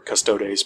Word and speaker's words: custodes: Sorry, custodes. People get mad if I don't pronounce all the vowels.